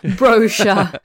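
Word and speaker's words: Broja: Det är ju dåliga nyheter för Brocha som... gud vad jag hamnade mitt emellan mitt uttal där. Brocha, säger man ju här borta.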